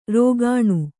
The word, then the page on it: ರುಗ್ಣ: kannada